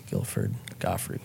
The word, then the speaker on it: Gottfried: Rest in peace, Gilbert Gottfried.